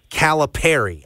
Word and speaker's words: Calipari: Cal himself says Calipari, as in pair of seagulls, pair of dolls. – So my gut was right. – Yes, Calipari. So if you do a pronunciation guide, like Cala-Perry.